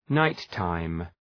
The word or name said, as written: night-time